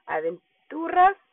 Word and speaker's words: Aventuras: Let me pronounce it correctly. I just want to pronounce it the Spanish way, Aventuras.